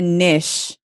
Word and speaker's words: niche: So Andres has a niche. Is that how the word is pronounced in American niche? English? – Niche. – Right. So he has For the troglodytes, niche.